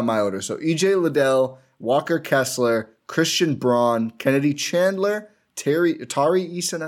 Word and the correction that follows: Braun: And actually, sorry, it's Christian Brown, not Braun. It's spelled Braun, pronounced Brown.